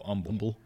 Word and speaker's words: Humble: of it's pronounced Humble to H. It's Humble. Silent, yes.